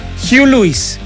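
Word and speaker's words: Huey Lewis: ¿cómo que se pronuncia? Para no equivocarme, don Miguel. Hugh Lewis, ¿ve? Y dice, Hugh and the News, claro.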